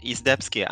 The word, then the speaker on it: Izdebski: Izdebski.